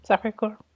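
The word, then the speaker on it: Sacré-Cœur: Sacre Coeur. Did I say that right? – Mm-hmm. Sacre Coeur. Mm-hmm.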